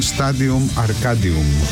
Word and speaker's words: Stadium Arcadium: Ο τίτλος ήταν Stadium Arcadium. Ακολούθησα τη λατινική προφορά, είμαι επηρεασμένος από τα πριν, από την Καρχιδόνα.